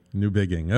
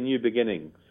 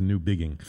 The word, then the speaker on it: Newbigging: Most people say new beginning, so you did a great job pronouncing it correctly. – Yes, I was paying attention to that. There's no N in there, so it was Newbigging.